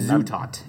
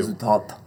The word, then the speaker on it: Zutaut: A little story about, like, so their A&R rep, Tom Zutaut, I believe. I'm probably saying that wrong. – And Zutaut. You gotta put the emphasis on the zoo. – Zutaut.